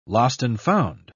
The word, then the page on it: lost-and-found: British English